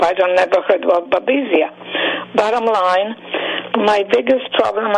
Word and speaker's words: Babesia: I'll spell it B-A-B-E-S-I-A. – Yeah, Babesia. – Babesia.